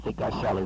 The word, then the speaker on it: Geisel: General Figueiredo was handpicked by General Geisel is the way it is pronounced, to be a successor.